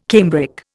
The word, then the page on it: cambric: Indian